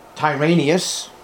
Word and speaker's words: Tyrannius: Tyrannius, sounds like Tyrannosaurus, which it is.